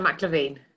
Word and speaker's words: MacLaimhin: We have to get the name right. McLevine, is that right? You say it, Sorcha. Uh, Sorcha MacLaimhin.